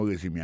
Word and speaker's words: résumé: Moi, ouais, non, c'est vrai, dans le curriculum vitae, regardez qui j'ai prononcé mon résumé, ainsi, il faut mieux résumer. Accent tombé sur le premier syllabe là.